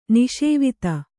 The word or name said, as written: ನಿಸೇವಿತ